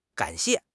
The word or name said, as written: gan3 xie4